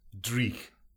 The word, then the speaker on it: dreich: My favourite word of all, dreich.